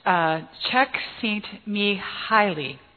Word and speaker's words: Csikszentmihalyi: He was born in Hungary, and his name is Mihai Csikszentmihalyi.